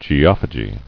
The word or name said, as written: Geophagist